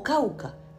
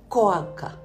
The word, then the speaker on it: Cauca: Y los voluntarios pronunciaban nombres de adquisición reciente y de contenido ignoto, Bolívar, Valledupar, Magdalena, Guajira o Quindío, pronunciando Quindío, o Cauca, pronunciando Coaca.